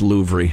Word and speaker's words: Louvre: Louvre and look at the paintings, it'd be exa- as as good as being there. I'm pretty sure it's pronounced Louvre.